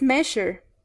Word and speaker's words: measure: The word is measure, to measure. – That's right. Measure. Measure. En este caso, la S suena francés, como el sonido en palabras franceses como genre o el nombre Jacques o je en je m'appelle.